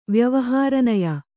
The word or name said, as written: ವ್ಯವಹಾರನಯ